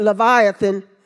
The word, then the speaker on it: Leviathan: it talks about that leviton. And his scales were so tight, they pronounce it Leviathan, that creature, they really don't know what it is.